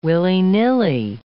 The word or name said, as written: willy-nilly